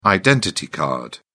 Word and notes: identity card: British English

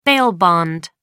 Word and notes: bail bond: American English

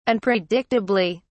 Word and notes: unpredictably: British English

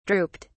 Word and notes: drooped: British English

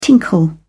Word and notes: tinkle: British English

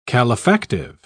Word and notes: calefactive: British English

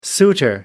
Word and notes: suitor: American English

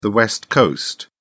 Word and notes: west coast: British English

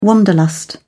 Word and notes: wanderlust: British English

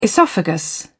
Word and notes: oesophagus: British English

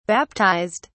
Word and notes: baptised: British English

